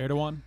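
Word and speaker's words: Erdoğan: We withdrew troops from Turkey that allowed Russia to come in and kind of take over via Erdogan or however you pronounce it. – Erdogan.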